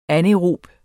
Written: anaerob

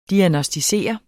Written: diagnosticere

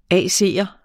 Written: AC'er

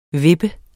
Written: VIPPET

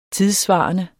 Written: tidssvarende

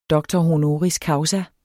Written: causa

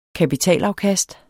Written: kapitalafkast

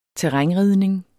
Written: terrænridning

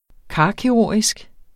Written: karkirurgisk

